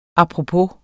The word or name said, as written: apropos